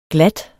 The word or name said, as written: glat